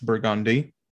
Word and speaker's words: Burgundy: Cause there's many of videos on, like we have a street named Burgundy, but that is not how it's pronounced. It's Burgundy.